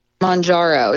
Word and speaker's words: Mounjaro: And stop me if I'm mispronouncing these, but Mounjaro and Zepbound, is that correct? – Mounjaro and Zepbound.